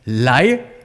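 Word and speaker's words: Lai (来): Auf Chinesisch spricht man es Lai aus, von unten nach oben. Lai bedeutet komm, komm her, komm zu mir.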